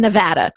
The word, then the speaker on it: Nevada: I had to, I wrote in my notes to make sure that it's pronounced Nevada, correct? – You got it just right.